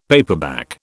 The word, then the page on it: paperback: British English